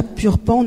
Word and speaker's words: Purpan: un bidonville situé à Purpan, non loin d'ici. J'ai bien prononcé, c'est bon? Oui.